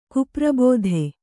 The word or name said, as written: ಕುಪ್ರಬೋಧೆ